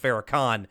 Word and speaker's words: Farrakhan: And he says it's like David Duke and Farrakhan are together, which I think is actually pronounced Farrakhan.